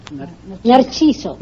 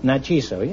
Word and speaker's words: Narciso: How do you say it? – Narciso. – Narciso, yes, that's pretty good.